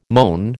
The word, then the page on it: moan: American English